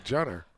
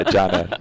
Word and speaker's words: Caner: C-A-N-E-R. – Oh, Janner. – Yeah, but it's a C. – It's C, and because of that is G. Yeah, Janner.